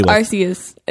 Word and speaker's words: Arceus: I hope I'm saying that right. – Arceus? – Arceus. – No, it's Arceus.